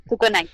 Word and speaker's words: Sukunai: Boku wa Tomodachi ga Sukunai o Sukaina. ¿Sukunai o Sukaina? Voy a buscarlo. – Sukaina. Sukunai, Sukunai, Sukunai.